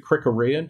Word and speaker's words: Krikorian: But no, Mark Krikorian, or I like to pronounce it Krikorian, because that's how I think American English would do it.